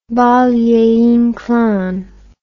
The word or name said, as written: Valle Inclan